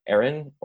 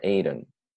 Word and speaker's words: Éireann: It's uh, Aaron. Mm-hmm. Uh, the pronunciation. Um, the pronunciation is either Aaron or Aiden, uh, which is similar to Aaron, and my friends are like kind of getting used to it.